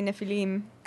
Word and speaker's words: Nephilim: The that's not how you say it. Say Nephilim.